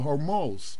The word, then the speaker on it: Hormuz: I believe that was the Gulf of Hormuz, if I'm pronouncing that correctly, yep.